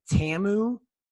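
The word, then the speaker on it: Ta'amu: It's Jordan, I think, Tamu is how you say his last name.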